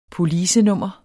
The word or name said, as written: policenummer